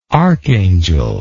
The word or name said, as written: archangelical